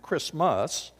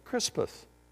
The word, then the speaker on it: Crispus: a guy by the name of Crispus. I love Bible names, especially ones I can pronounce. Crispus, what a cool name. Not Christmas, but Crispus.